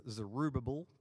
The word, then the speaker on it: Zerubbabel: There was Zerubbabel, that's how you say his name, who was the king, and Joshua the priest.